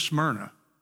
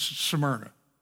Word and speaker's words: Smyrna: Now I pronounce this Smyrna. I don't know how you guys do with that, okay? There's the city in Tennessee that helps us do that. It's Smyrna.